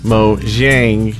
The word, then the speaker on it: Mojang: I do not know if that's true or not, but the pronunciation of the company name should be Mojang.